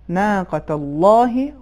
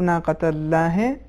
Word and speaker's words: ناقۃ اللہ: تو صحیح تلفظ اس کا یہ ہے ناقۃ اللہ و سقیاہا اس کا مطلب ناقۃ اللہ یہ صحیح نہیں ہے